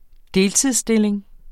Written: deltidsstilling

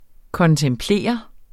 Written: kontemplere

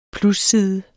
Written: plusside

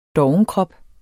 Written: dovenkrop

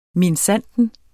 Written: minsandten

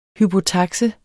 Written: hypotakse

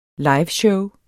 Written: liveshow

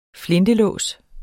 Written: flintelås

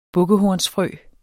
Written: bukkehornsfrø

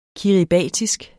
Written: kiribatisk

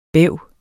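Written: BÆV

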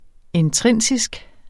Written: intrinsisk

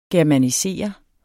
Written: germanisere

